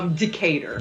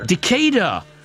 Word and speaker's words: Decatur: You grew up in a place, is it Decatur? Am I pronouncing that correctly? – Decatur. – Decatur. Well, I've been pronouncing that wrongly for all my life.